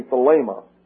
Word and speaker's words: thelema: T-H-E-L-E-M-A. It's actually thelema. That would be a good anglicized translation, but thelema is the Greek pronunciation.